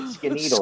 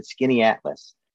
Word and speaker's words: Skaneateles: I said Skaneateles, and I'll spell it. And they're like, that's like skinneedle. I said, no, no, no, sorry, it's not skinneedles.